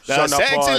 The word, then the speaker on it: Sean Paul: Yeah. That's how you have to say his name. – Sean Paul.